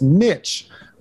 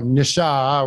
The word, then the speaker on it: niche: You said it correctly. It's niche, right? It's not niche or nisha or whatever the term is, because that would ruin the phrase.